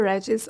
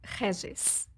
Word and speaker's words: Regis: I mean, either Regis or like in Portuguese, it's really Regis.